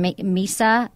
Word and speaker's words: Mesa: He's from Mesa, Arizona. How do you say that? – M-E-S-A? – Mesa. That's right.